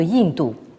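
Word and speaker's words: Yintou (印度): qui se prononce en désignation moderne sous le nom de Yintou. Yintou, c'est donc le nom moderne que les Chinois donnent à l'Inde.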